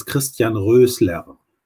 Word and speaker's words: Christian Roesler: Yes, my name is Christian Roesler.